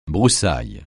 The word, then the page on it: broussaille: francais